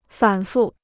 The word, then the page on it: 反复: fǎn fù